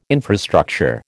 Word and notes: infrastructure: American English

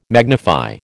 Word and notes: magnify: American English

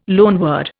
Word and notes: loanword: Indian

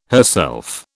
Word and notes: herself: British English

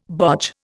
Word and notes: bodge: Indian

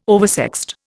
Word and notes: oversexed: Indian

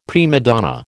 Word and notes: prima donna: American English

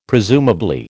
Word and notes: presumably: American English

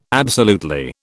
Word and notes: absolutely: British English

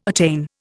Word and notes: attain: Indian

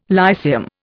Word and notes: Lyceum: Indian

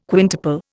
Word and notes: quintuple: Indian

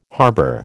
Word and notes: harbour: American English

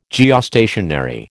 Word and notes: Geostationary: American English

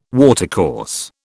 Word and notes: watercourse: British English

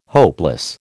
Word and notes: hopeless: American English

